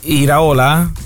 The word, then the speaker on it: Iraola: Allora chiudiamo questa prima ora con una notizia che arriva dalla Spagna, perché Iraola no, era uno degli allenatori che si Iraola con l'accento sull'A? – Sì, Iraola? – Iraola forse, no? – Ola.